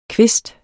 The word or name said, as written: kvist